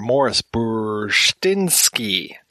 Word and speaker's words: Maurice Bursztynski: Also back in the booth is Mr. Maurice Bursztynski.